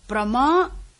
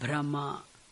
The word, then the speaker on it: Prama: Prama means knowledge. The word Prama, we'll be very careful in pronouncing it. We should not write it Brahma. Brahmaha, confusion. Prama, knowledge.